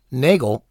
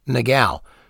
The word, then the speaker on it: Nagell: Russell says that it's okay to pronounce it either as Nagell or Nagell, because the man himself pronounced his own name both ways.